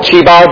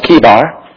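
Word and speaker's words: Chebar: Throughout the time of preaching, I might call it Chibar because that's what I've always called it. But the correct pronunciation is Kibar.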